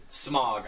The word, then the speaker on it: Smaug: Smaug. I'm going to call him Smaug because that is the appropriate pronunciation. – So none of this Smaug business.